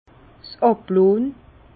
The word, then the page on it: 's Àblonn: Bas-Rhin; Herrlisheim